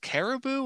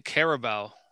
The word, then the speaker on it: Carabao: Or it's Caribou, but I look at it like, is that Caribou or Carabao? – It's spelled wrong if it's Caribou. Or maybe I call it. – Well, maybe they're spelling it right and we're spelling it wrong. – So I'm calling it Carabao.